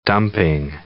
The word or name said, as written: dumping